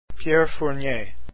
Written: Fournier

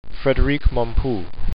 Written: Mompou

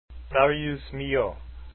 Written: Milhaud

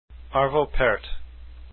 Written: part

